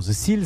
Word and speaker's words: Cyl: SIL, ça s'écrit C-Y-L comme et en anglais Motors.